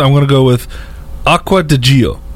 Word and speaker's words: Acqua di Gio: Acqua di Gio. – Everybody knows the cologne. – Acqua di Gio.